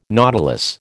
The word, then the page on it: nautilus: American English